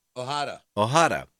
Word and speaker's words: Ojeda: O-J-A-D-A. How do you pronounce that? – Ojeda.